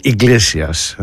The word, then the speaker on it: Iglesias: Ο Enrico Iglesias, έτσι τον προφέρουν, Iglesias, στο χείρο.